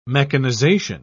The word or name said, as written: mechanization